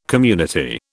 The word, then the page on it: community: British English